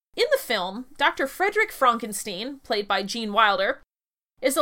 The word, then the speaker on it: Frankenstein: And, to dissociate himself from his ancestor, Frederick insists that his surname is pronounced Frankenstein. When